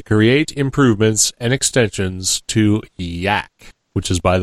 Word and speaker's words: YAAC: He says, YAAC, or YAC as he pronounces it,